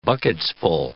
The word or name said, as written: bucketsful